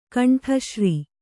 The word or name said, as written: ಕಂಠಿಸರ